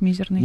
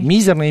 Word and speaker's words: мизерный: Можно и так-так ударять мизерный. – Мизерный и мизерный, да? Угу. Подвижное ударение, значит.